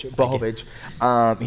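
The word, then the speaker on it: Błachowicz: And same as Israel Adesanya, I felt like when he went up and fought, Jakovic, did I say it right? – Blachowicz, I guess. – Blachowicz.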